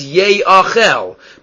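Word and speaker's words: Ye'achel (יאכל): If you just had in the Torah, Yud Aleph Chaf Lamid, usually that's pronounced Yochal. So that's how it's written. It's written as Yochal, but it's read as Ye'achel.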